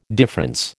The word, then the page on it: difference: American English